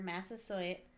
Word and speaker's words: Massasoit: Massasoit? Yeah, like the college. – Oh. So the Wampanoag leader, Massasoit...